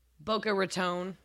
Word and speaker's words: Boca Raton: Baco Ratton? I ain't saying that right, am I? – Boca Raton.